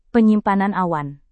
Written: penyimpanan awan

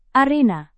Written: arena

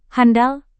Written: handal

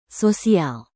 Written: sosial

